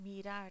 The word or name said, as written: mirar